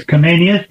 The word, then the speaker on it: Comenius: His name was John Amos Comenius. Did I say that right? – Yes, Comenius. Yeah, that's him.